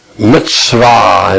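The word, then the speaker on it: Mitzvah (מצוה): Mitzvah in the Hebrew. M-I-T-S-V-A-H. Pronounces mitz, M-I-T-S plus vah, V-A-W.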